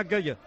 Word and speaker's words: Gueye: A mí me ha dicho Quique Iglesias que como no lleva acento en la E, deberíamos usted decir... – Gueye. – Idrissa Gana Gueye. – Gueye, vale.